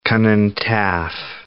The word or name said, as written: Rhondda Cynon Taff